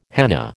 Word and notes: henna: American English

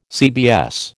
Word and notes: CBS: American English

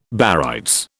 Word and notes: barytes: British English

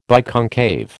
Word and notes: biconcave: American English

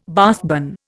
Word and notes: Bath bun: Indian

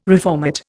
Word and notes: reformat: Indian

